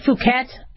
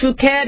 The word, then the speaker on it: Phuket: and then there was a B Phuket. It's not pronounced Phuket.